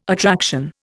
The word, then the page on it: attraction: Indian